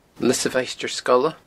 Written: Lios a' Mhaighstir Sgoile

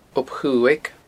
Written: Ob Chuaig

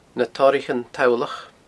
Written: Na Toraichean Tollach

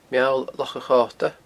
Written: Meall Loch a Chota

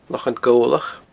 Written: Lochan Gobhlach

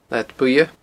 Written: Leathad Buidhe

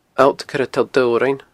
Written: alltcoiretolldobhrain

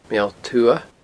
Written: Meall Tuath

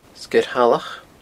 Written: Sgeir Shalach

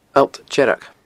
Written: alltdearg

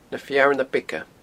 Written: Na Fearna Beaga

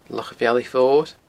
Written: Loch a' Bhealaich Mhoir

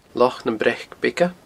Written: Loch nam Breac Beaga

